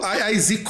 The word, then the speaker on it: YYZ: Aliás, você, você, você falou YYZ correto, cara. Eu. Ou Zed, se você quiser a pronúncia britânica, que eu não lembro se no Canadá falam Z ou se falam Zed.